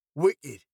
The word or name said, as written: wicked